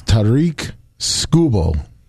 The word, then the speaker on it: Tarik Skubal: It's Tarik Skubal.